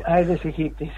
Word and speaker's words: Aedes aegypti: Aedes aegypti creo que se llama. – Aedes aegypti, sí, es un mosquito particular.